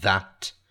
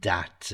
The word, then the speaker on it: that: That, and all those words, that, this, these, those, okay, they're relative pronouns, and we have to make sure we pronounce that TH, that, this, these, those. Okay? It's really important, that TH sound, not dat and dees and dis.